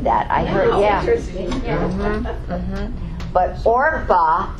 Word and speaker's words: Orpah: Oprah, did I say that right? No, I said it wrong. I always do this. Orpah, thank you.